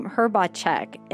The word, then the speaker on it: Hrbacek: You have a very unique last name, Hrbacek, and it's not right.